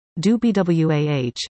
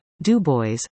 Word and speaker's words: Du Bois: "No, Grandpa, the correct pronunciation is Du Bois," I said with confidence, and he replied, "W.E.B. Du Bois pronounced his name, Du Bois, so that is how you should pronounce it.